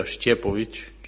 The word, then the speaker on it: Šćepović: Por eso llevo en la camiseta Stefan, que es lo más fácil pronunciarlo, pero bueno, el apellido se pronuncia Šćepović, que es bueno, es difícil de pronunciarlo.